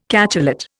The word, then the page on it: cachalot: Indian